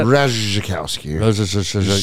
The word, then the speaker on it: Ratajkowski: Ratajkowski.